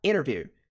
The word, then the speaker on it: interview: Americanos provavelmente pronunciariam essa palavra aqui, interview, sem a pronúncia do T.